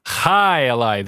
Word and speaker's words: Jai Alai: Jai Alai. Am I pronouncing that correctly? Jai Alai. Sorry. Hi, Eli. The J is pronounced softly. Hi, Eli, with softly.